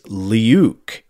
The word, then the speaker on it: leuk: In Dutch, the word is leuk. It has a wonderfully difficult pronunciation for an English speaker, something like..." The way he spells it is L-E-H-O-O-K. So Leuk, that's what I'm trying.